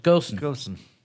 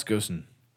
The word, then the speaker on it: Ghosn: How do you spell it? – G-H-O-S-N. – That's like ghost, but with an N on the end of it. – Gosen. – Carlos Gosen. – That'll do for now.